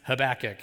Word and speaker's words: Habakkuk: that's the way that word is pronounced. Habakkuk.